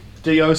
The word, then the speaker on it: Djo: D-I-O? – D-Y-O. – D-Y-O. – Sorry. D-J-O. – D-J-O. – Yeah. – It was a good silent J pronunciation you did just then.